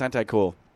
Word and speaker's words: AntiQCool: Yeah, and it's not Antique-Cool. They were, I think, I remember them saying, no, it's pronounced Anti-Cool.